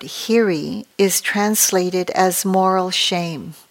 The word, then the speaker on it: Hiri: In the ancient language of Pali that the Buddha's teachings were translated from, these are known as Hiri, and it's spelled H-I-R-I, and Ottappa, O-T-T-A-P-P-A, for those of you who are interested, but you don't have to remember that.